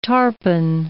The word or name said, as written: tarpon